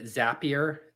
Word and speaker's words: Zapier: Zapier. It's spelled Z-A-P-I-E-R.